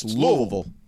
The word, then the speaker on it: Louisville: It's not Louisville. It's Louisville.